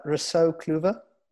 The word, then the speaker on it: Rousseau Kluever: So Rousseau Kluever.